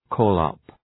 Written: call up

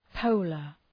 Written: polar

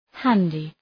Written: handy

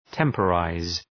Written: temporize

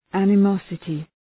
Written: animosity

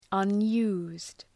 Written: unused

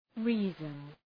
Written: reason

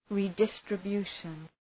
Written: redistribution

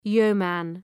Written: yeoman

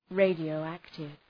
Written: radioactive

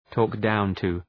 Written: talk down to

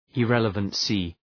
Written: irrelevancy

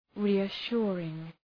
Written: reassuring

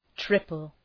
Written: triple

